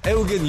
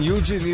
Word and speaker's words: Eugene: Oni tvrde da su posljednjih godina članovi uprave kompanije, kao i njen suosnivač, Eugen Eugene ili Ežen Kasperski, kako ko izgovara, učestvovali u osmišljavanju raznih sabotaža koje su bile usmerene prema konkurenciji.